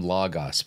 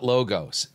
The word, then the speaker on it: Logos: And it's not Logos. Anybody who pronounces the software Logos as Logos knows that, tells me you don't know Greek, okay? Because Omicron is a short O, not a long O.